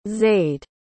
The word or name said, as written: zaid